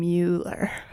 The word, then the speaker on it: Mueller: I do like that you pronounce his name Mueller. – Well, like you, Ferris Bueller.